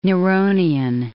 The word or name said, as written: Neronian